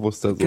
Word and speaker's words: Worcestersoße: Und wir haben im Osten ähm, uns gedacht, dass es richtig ausgesprochen Worcestersoße genau, ist. Genau, richtig.